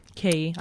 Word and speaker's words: Quay: Key, I think it's pronounced.